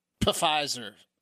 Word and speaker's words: Pfizer: So, I think you got the company name wrong. It's Pfizer. – Pfizer, yeah. Yes. For some reason, they put a silent P at the front of Pfizer, but it's amazing news, right, guys?